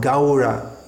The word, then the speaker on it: Gaura: Shivasadi, not Shivasadi, Shivasadi, Gaura Bhaktavrinda, Gaura, Gaura, this sound, A-U, is pronounced something like the sound in how, H-O-W, how, Gaura, Gaura.